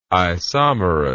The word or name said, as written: isomerous